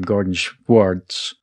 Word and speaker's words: Gardenswartz: Well, this is an extraordinarily special show because we have a writer on the show, Noah, who you'll hear me struggle with the pronunciation of his last name, Gardenswartz. I want to do a Schwartz. Noah Gardenswartz. Schwartz. Still can't say it.